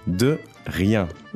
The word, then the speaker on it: de rien: De rien.